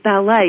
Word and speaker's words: Ballet: Laura, I believe your last name is Ballet. Is that correct? – Yes, like the dance.